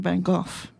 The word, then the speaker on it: Van Gogh: His name is spelled V-A-N-G-O-G-H. So in Australia, he's pronounced Vincent Van Gogh.